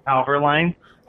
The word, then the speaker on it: Halverline: How do you pronounce the name of the company? – Halverline. – Oh, it's Halver. – H A L. Yeah, Hal, and then Vor. – Halverline. Okay.